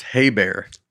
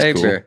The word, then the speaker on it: Eber: In Genesis 10:24, there is a name spelled E-B-E-R. Eber, I guess. In English, it is pronounced Haber. Oh. – All right. – Haber.